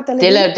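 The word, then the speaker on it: Delevingne: Und äh, Cara Dele, Delevin, Delevin, wie spricht man der aus? Cara Delevin. Delevin, er hat jetzt auch so gesagt, ja.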